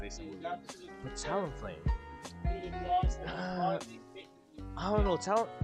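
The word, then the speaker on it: Talonflame: Am I saying that right? Talon Flame. – Talon Flame. Yeah.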